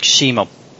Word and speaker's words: Cusimo: I pronounced the name Alex Cusimo. How close is your pronunciation to Alex's surname? Does that sound about right to you? – That sounds about right, yeah.